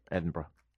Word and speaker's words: Edinburgh: Are you still calling it Edinburgh? – Edinburgh. How do you say it? – Edinburgh. – Edinburgh. That's what I said.